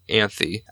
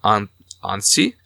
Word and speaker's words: Anthy: I'm going to say her name as Anthy, even though in Japanese it sounds like they're saying Anshi. – Anshi.